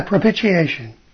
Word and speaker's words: propitiation: There's a word that I've seen in, I'll call them Christian Bibles, that it's called, it's propitiation. And I don't know if I'm even saying it right. – Yeah, propitiation.